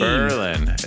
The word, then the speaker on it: Berlin: Berlin is how they say it.